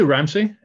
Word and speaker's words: Ramsøy: You can say Ramsey. It's actually a Scottish name that was made into Norwegian for some reason. So, it's a mess.